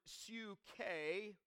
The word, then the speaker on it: psyche: Then the second word for life in the New Testament is the word psyche, starting with a P, silent P,